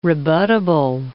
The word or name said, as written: rebuttable